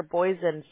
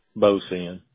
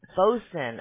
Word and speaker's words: Bozen: Yeah, without a doubt, because that's going to maximize the jobs that come to – is it Bozen or Boysen Services? How do you pronounce that? – It's Bozen. – Bozen. – Yeah, it's Bozen. Yeah.